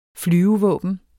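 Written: flyvevåbnet

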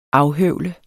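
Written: AFHØVLER

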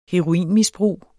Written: heroinmisbrug

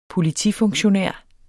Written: politifunktionær

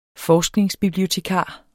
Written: forskningsbibliotekar

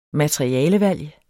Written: materialevalg

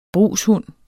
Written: brugshund

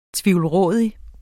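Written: TVIVLRÅDIG

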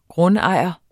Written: Grundejerne

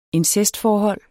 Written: incestforhold

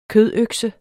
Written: kødøkse

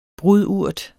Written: brudurt

